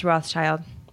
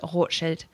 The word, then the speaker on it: Rothschild: The Rothschild, in English it's pronounced Rothschild, but in German it is Rothschild.